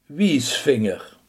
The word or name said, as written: wijsvinger